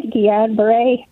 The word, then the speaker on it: Guillain-Barré: Yeah, I had Guillain-Barré. That's how you pronounce it.